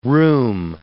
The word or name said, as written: rheum